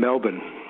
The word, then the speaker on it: Melbourne: And the thing about that is that the A in Falcon and the E in Melbourne are pronounced exactly the same way. So Falcon and Melbourne. – Falcon, oh yeah, it's rhyming slang. – It's in, well, it's in between, the vowel sound is in between an A and an E.